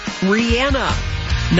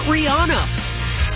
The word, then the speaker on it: Rihanna: You know, I always get a little bit of flack on how I pronounce her name, Rihanna, not Rihanna. Surprisingly, that's how she says her own name.